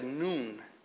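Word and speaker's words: noon (نون): Sí, N, como de en inglés se pronuncia esa letra, en árabe se pronuncia como mediodía, en inglés es noon, ¿sí?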